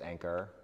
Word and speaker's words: Anker: Anker. You got it. – There we go. Perfect. They, people confuse it with the cell phone chargers, Anker.